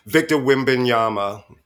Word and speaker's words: Wembanyama: Victor Wembanyama.